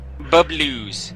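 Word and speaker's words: Bubblews: It's Bubble with a WS at the end. Bubblues. There we go.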